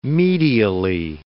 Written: medially

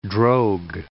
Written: drogue

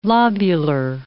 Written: lobular

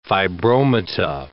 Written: fibromata

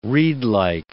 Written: reedlike

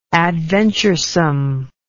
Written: adventuresomeness

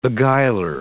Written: beguiler

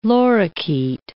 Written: lorikeet

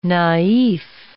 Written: naif

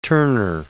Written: Tourneur

